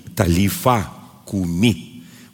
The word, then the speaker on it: талифа куми: Эти слова принадлежат нашему Господу Иисусу Христа, Христу, талифакуме. Или если говорить по правильно ставить ударение, тали, талифа куми. Вот так это произносят.